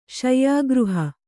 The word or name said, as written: ಶಯ್ಯಾಗೃಹ